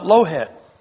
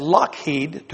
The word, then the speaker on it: Lockheed: That's not Lowhead. That's Lowhead to an American. But that's Lockheed to a Scot.